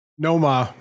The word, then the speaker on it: Nomar: You say it. – Nomar. – That's how it's said.